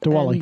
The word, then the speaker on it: Diwali: So I want to talk about the Diwali, however you say it. – Diwali. – So I did say it right the first time.